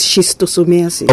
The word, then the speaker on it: schistosomiasis: Schizotosomiasis. No, that is schistosomiasis.